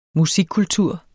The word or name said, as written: MUSIKKULTUR